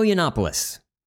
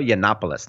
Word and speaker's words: Yiannopoulos: Of course, Milo Yiannopoulos, is that how you say his name? – Milo Yiannopoulos. – Milo Yiannopoulos, thank you.